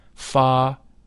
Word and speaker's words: Pharaon: The whole mantram Pharaon is divided into three syllables, thus Pharaon. The Fa is that of the musical scale. The Ra is a deep sound. It should be vocalized with a rolling R, Ra.